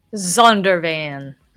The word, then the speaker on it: Zondervan: Zondervan.